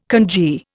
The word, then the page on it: kanji: Indian